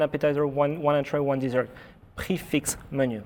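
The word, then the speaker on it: prix fixe: Pronounce that for me. – Yeah. – Is that prefix? – How do you pronounce that? – Come on. What should people say? – Prefix. – Prefix. – Prefix. Okay.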